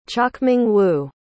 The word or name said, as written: chochmingwu